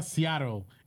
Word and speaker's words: Seattle: Y no, no, pero se pronuncia Seattle en inglés.